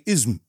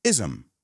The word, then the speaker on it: ism: Say it with me ism, ism because I've heard students say ism, ism, you know, make all kinds of weird sounds because there's no vowel there. Think of it like a little e over there, a short e, ism.